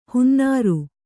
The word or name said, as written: ಹೊನ್ನಾರು